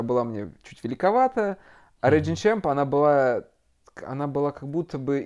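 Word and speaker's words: Reigning Champ: И вторая была коллаб с канадским брендом Regin Champ. Называю, вроде так правильно произносится. – Regin Champ.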